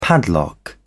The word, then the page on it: padlock: British English